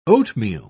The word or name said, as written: oatmeal